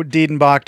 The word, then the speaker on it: Dedenbach: Did I say that correctly? – Yeah, Marco Diedenbach, or the Master the Mountain. – Both. – You got it all right.